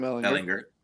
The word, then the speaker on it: Ehlinger: Ellinger, if I'm saying that right, he's Sam Ehlinger. – Ehlinger, former Texas.